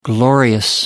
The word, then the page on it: glorious: American English